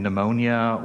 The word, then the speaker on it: pneumonia: You see, the P is silent, like in pneumonia or pterodactyl.